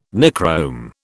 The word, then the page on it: Nichrome: British English